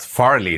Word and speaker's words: Farley: Ah, ich hab's falsch ausgesprochen und gehört. Farley, nicht Farley. Farley.